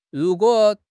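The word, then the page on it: ru2 guo3: tone 2; tone 3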